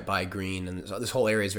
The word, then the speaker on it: Gruene: She had been to New Braunfels before, and we went to Green, Texas, which is spelled G-R-U-E-N-E, which is how you say green in German, Grün.